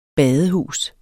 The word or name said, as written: badehus